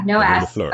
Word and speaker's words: La Rue de Fleurs: So you are the maker behind, I hope I say this right, La Rue de Fleurs. – La Rue de Fleurs. But yeah. – Okay. Silent S.